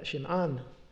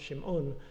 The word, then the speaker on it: Shim'an: Interestingly, his name, Shim'an, suggests that he was Jewish. And it is Shim'an, not Shim'un, because the waw would have been shown in the Aramaic, but the alif, the R, the long A, never is shown internally.